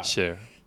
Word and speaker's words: share: Y la, la cuestión es que el punto clave es la propiedad de Sari o de Shar. Share. Share, share, eso, cómo se pronuncia.